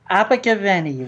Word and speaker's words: Abergavenny: He was born in the United Kingdom in the South Wales market town of Abergavenny, how do you say that? – Abergavenny, Rob.